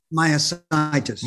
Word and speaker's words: myositis: Because I know you come out about this IBM, I'm going to pronounce it wrong, inclusion body mitosis. Am I saying it right? – Myositis. – Myositis. I apologize.